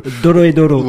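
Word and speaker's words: Dorohedoro: Alors, je vous laisse le soin de la prononciation de ce titre. – Oui, donc ça s'appelle Doro et Doro. – Oui, mais vous me l'avez prononcé... – Doro et Doro. – Ouais, voilà, ouais, je préfère comme ça. – À la japonaise, oui, voilà.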